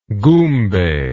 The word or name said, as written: goombay